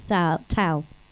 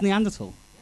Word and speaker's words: Neanderthal: So we're with Thal. – It's tall. So it, Neanderthal. – What sort of scientist are you then? Oh, okay, brilliant. So it is Neanderthal.